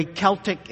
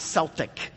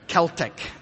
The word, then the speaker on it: Celtic: By the way, Celtic is not Celtic. Celtic. And by the way, if you ever see it spelled with a K, we don't have K's in Gaelic. It's Celtic, all right?